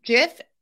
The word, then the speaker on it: GIF: GIF may be pronounced either as a soft G as in giant or a hard G as in graphic.